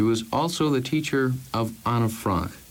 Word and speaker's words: Anne Frank: It's a pleasure to hear you say Anne Frank in the right pronunciation.